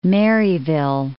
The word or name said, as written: Maryville